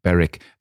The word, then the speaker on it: Berwick: Now, we would say Berwick because it's spelled B-E-R-W-I-C-K. So there is one just on the Scottish border, and we called it Berwick, but it might be Berwick or something.